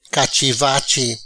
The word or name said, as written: Cachi vachi